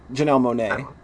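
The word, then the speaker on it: Monáe: After this week, I actually started with Janelle Monae. Is that how you say it? – Janelle Monae.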